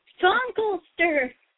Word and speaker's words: Funkelster: are we doing bad pronunciations of the Dutch name? – I had so. – To. It's Funkelster.